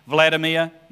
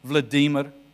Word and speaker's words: Vladimir: Vladimir? No. Vladimir. Boris Yeltsin? No. Boris. We get the emphasis on the wrong syllable. You've got to get it on the right syllable.